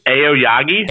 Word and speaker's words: Aoyagi: Next up, you had the six-man tag, the Kongo team of Nakajima, Kano, and Soya versus Kento Miyahara, Yuma Yogagi, did I say it right? – I think it's Aoyagi.